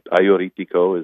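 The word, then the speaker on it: Agiorgitiko: Aioritiko, which is also called St. George, but Aioritiko is how it's correctly pronounced.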